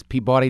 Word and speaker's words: Peabody: Is Peabody different than? – Peabody. – Oh, sorry. – Which, no. You did not. Peabody. You said it right, and then I said it the way we say it.